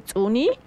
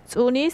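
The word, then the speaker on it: ts'uni: Bueno, entonces colibrí se pronuncia de acuerdo a las variantes, tsuni o tsuno. Entonces, ¿preparados? ¿Listos? Voy a empezar a deletrear. – Listísimo. – Entonces, tsuni se escribe con la letra T.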